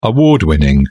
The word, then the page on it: award-winning: British English